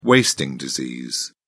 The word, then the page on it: wasting disease: British English